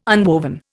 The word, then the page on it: unwoven: Indian